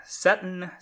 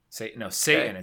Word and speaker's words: seitan: Vegan, it's Seton. Satan. It's Satan. That's how you pronounce it. – No, it's the vegan food, S-E-I-T-A-N, is pronounced Satan.